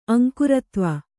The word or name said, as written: ಅಂಕುರತ್ವ